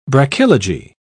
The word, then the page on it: brachylogy: British English